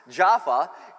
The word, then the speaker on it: Jaffa: And then there's Jaffa, it's pronounced. Jaffa, and that is where Peter's hanging out.